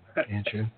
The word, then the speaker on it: Anshou: Anshou? – Yeah, you did very well.